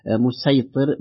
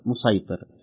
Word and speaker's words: مسيطر: نستطيع أن نقول مسيطر بالسين أو بالصاد مسيطر.